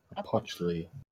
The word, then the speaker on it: Opochtli: The T is silent. Opochtli.